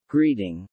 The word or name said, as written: greeting